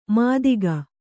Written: ಮಾತಿಗ

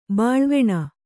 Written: ಬಾಳ್ವೆಣ

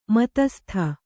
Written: ಮತಸ್ಥ

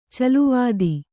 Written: ಚಲ್ಲವತ್ತ

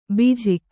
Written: ಭೋಜಕ

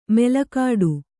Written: ಮಲಗಟ್ಟು

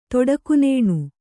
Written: ತೊಡಕುನೇಣು

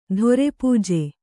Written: ಧೊರೆಪೂಜೆ

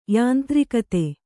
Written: ಯಾಂತ್ರಿಕತೆ

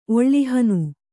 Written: ಒಳ್ಳಿಹನು

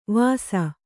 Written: ವಸಾ

